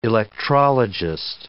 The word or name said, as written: electrologist